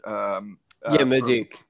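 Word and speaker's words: Mudryk: I can't say his name, Murdoch. I can't say his name right. Is it Murdoch? Murdick? – Murdick. Yeah. – Murdick.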